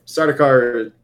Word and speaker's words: Sardaukar: Sardaukar.